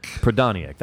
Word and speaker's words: Prodaniuk: Pronounce his last name like it's properly pronounced. Prodaniuk. – Prodaniuk.